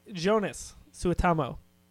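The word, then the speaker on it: Joonas Suotamo: So we see Chewbacca, and he's played by Jonas Suitamo.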